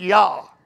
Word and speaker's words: Jah: The J-A-H on the end of his name, Elijah, that's God's name, Yah, J-A-H, pronounced Yah.